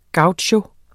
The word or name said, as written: gaucho